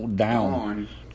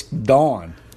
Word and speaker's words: Dawn: Down. Down. It's spelled like Down, but it's Dawn.